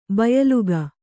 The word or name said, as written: ಬಾಯಿಲೆಕ್ಕ